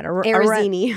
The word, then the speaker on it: Arezzini: Arezzini.